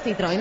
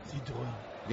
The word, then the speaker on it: Citroën: ¿Te queda mejor la pronunciación francesa a ti? Sí. – Bueno. – Citroën. Dilo en francés. – Citroën. – Ahora te ha quedado mejor a ti, la verdad.